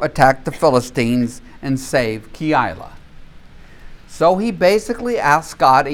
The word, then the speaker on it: Keilah: When David was told, look, the Philistines are fighting against Keilah. That's how you pronounce it, I looked it up. Keilah.